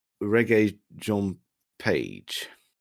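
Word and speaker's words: Regé-Jean Page: And it's Reggae John Page,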